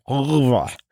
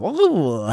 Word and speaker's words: oeuvre: And he said, oeuvre. And I said, wait, say it again? And he looked up to me and said, oeuvre. I'm like, okay, now I know how to pronounce it.